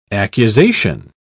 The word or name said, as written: accusation